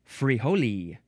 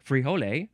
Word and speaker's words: frijole: The next word is frijole, or you could say, which I think is probably more accurate, frijole, or you can also take off the E at the end and just say frijol, or frijol. Emphasize either one, and it is spelled F-R-I-J-O-L-E. with or without the E.